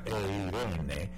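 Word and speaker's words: eirene (εἰρήνη): La palabra paz en el griego es una palabrita que se pronuncia eirene y significa unir.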